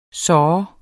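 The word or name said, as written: såh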